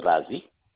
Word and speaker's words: Razi: First name is Razi.